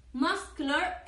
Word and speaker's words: muscular: Muscle es con una S, pero muscular, muscular es sk, musk. O sea, son dos palabras de la misma familia, pero se pronuncian diferente.